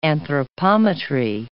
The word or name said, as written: anthropometry